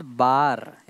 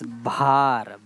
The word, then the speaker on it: भार: It's not bar, it's भार.